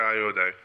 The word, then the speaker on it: Ayoade: Richard Ayoade.